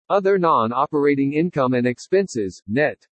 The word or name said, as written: OTHER NON-OPERATING INCOME AND EXPENSES NET